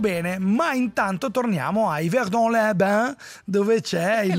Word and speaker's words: Yverdon-les-Bains: Ma sei bellissimo, sei bravissimo a pronunciarlo. – È l'unica cosa che so fare in francese, dire Yverdon-les-Bains, è quello, è così.